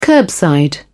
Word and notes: curbside: British English

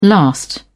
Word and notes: last out: British English

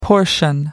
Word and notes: portion: American English

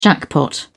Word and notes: jackpot: British English

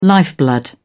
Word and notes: lifeblood: British English

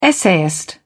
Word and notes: essayist: British English

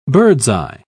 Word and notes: bird's-eye: British English

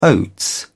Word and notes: oats: British English

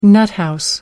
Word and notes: nuthouse: American English

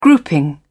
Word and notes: grouping: British English

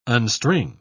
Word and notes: unstring: British English

